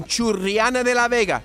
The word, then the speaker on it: Churriana de la Vega: Y me, eh, después de hacer una búsqueda anoche me doy cuenta de que en Churriana de la Vega. ¿Lo he dicho bien, Pepe? Sí. – Ha dicho estupendamente ahora mismo. Perfecto.